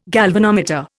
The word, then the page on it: galvanometer: Indian